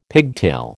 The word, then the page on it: pigtail: American English